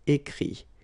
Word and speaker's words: écrit: IRE here, IT, but then phonetically it's I as well because you don't pronounce the final T, okay? Écrire, écrit. I-R-E-I-T, same group. Dire, dit. Okay, then E-T-T-R-E will give you this I-S, but then you don't pronounce the S, so you get I.